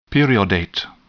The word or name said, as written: periodate